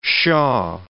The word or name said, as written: shah